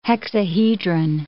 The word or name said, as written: hexahedron